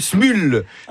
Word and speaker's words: Smule: Ça s'appelle Smule. Ça s'écrit S-M-U-L-E, avec plusieurs centaines de millions de téléchargements.